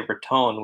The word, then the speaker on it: Raton: Well, my experience is that people who are from there say Raton, which is closer to the original Spanish pronunciation, and people who aren't familiar with it say Raton.